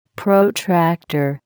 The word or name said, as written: protractor